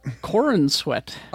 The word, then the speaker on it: Corenswet: Did you say Corenswet? – Corenswet.